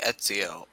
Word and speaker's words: Ezio: You guys can find me on Twitter at Ezio21God, Ezio, not Esio. That's when people mix it up sometimes.